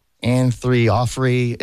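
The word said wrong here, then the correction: Orianthi: It's the Michael, whatever her name. – Orianthe. – Anthree Offrey. I wish her name was Anthree Offrey. – Yeah. Orianthe.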